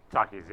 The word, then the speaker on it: Takis: Takis, yeah.